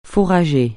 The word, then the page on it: fourrager: francais